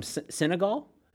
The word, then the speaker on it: Senegal: I can't read the map right here, but from Senegal, mm-hmm. I say that right? – Yeah.